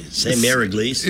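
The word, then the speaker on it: Sainte-Mère-Église: Sainte Mère Église. – It's like Saint S A I N T E dash M E R E dash E G L I S.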